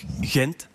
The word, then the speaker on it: Gent: Ja, dat is mij ooit verteld, dat ze dat daar niet uitspreken als Gent, maar als Hent. Blijkt helemaal niet zo te zijn? – De West-Vlamingen doen het.